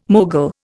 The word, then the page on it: mogul: Indian